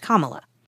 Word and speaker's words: Kamala: He also attacked his new opponent, whose name is pronounced Kamala.